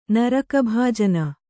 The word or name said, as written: ನರಕಭಾಜನೆ